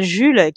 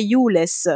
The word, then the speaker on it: Jules: comment ils disent Jules Roule euh, non, non, non, c'est plus jules Joule, alors la, prononciation vraiment littérale, ce serait Youless, ce qui est un petit peu très très loin de mon Jules.